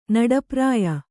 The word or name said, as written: ನಡಪ್ರಾಯ